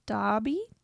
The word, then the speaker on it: Dabi: The day of the convention comes and she showed up at my house cosplaying as Dobby, D-A-B-I, from M-H-A. – My Hero Academia. My Hero Academia, so close. Baby. – Specifically a look he has later in the manga. Did I say that right? – Yeah.